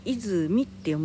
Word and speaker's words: 出水: 出ると水って書いて泉って読む